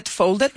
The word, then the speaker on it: folded: folded. Remember to pronounce that last syllable.